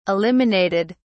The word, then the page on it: eliminated: British English